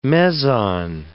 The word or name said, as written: meson